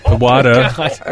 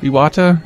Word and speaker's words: Iwata: Iwata. – I really didn't want to correct you because I didn't want to throw you off. – How do you say it? – Iwata. – Iwata? – Yeah. Iwata-san.